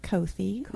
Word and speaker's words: Kothi: Yes, it's pronounced like Kathy with a long O. – Uh-huh. – Kothi Zahn.